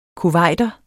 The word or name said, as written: kuwaiter